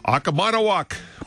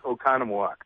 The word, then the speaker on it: Oconomowoc: Today's guest is the pastor of St. Paul Lutheran Church in Oconomowoc, Wisconsin. I bet I pronounced it right this time. Now, I have my questions. – You totally wrecked it. It's Oconomowoc.